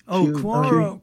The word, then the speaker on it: Quora: A couple of years ago, I started an account on, I mispronounce this all the time. It's Quora or... – Quora.